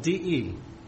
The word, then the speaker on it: de: It's spelled D-E, D-E, pronounced de.